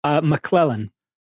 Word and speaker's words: McClellan: McClellan.